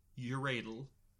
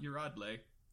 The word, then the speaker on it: Yuradle: Y-U-R-A-D-L-E, Uradel. – Okay. – It is not Uradle.